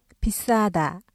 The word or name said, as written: 비싸다